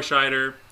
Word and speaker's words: Scheider: Roy Scheider, did I say his name right? Scheider? Mm-hmm.